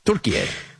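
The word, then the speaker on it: Türkiye: It's the Turkish pronunciation of Turkey. Turkiye.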